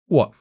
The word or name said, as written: 我